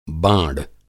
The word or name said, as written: बाँड़